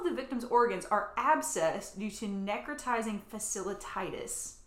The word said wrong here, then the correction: fasciitis: I think I'm saying that right. Uh, uh, fasciitis. – Fasci- fasciitis, that's right.